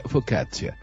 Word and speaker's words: focaccia: Sí, a focaccia. Se, se, pronuncia focaccia.